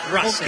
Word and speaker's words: Russell: No, pronunciación la real es Russell. – Russell. – Sí, no lo muchos le dicen Russell, está perfecto, pero la pronunciación correcta es Russell.